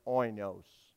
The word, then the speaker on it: oinos: which is pronounced oinos.